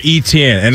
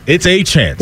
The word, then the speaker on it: Etienne: He is no longer Etienne. – And it's pronounced exactly the way Devon pronounces his name? – He's going Achan. Yeah. – Devon Achan. Travis Achan.